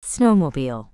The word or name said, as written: snowmobile